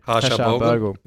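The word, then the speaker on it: Harsha Bhogle: Harshal Bogle. Is that how you say it? – Yeah, cricket fan. – Harshal Bogle. Yep.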